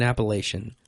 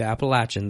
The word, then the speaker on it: Appalachian: She said, well, I'm from Ohio, and so I grew up saying Appalachian. I thought, oh, no. She said, but if you ask anybody from here, they'll tell you Appalachian. They say, you just say it like you're, I'm going to take an apple and throw it at you.